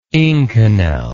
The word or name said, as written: inconel